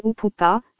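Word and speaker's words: upupa: Mais Pline de U faisait, ou, et devait prononcer oupoupa, comme me l'enseigne le cri imité dans le nom.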